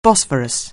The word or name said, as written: Bosphorus